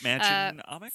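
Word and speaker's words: Mädchen Amick: I just don't know how to say her name. Matcham Amick? – Yes. – Amick? Matcham Amick? – From Twin Peaks.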